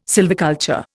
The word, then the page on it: sylviculture: Indian